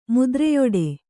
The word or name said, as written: ಮುದ್ರೆಯೊಡೆ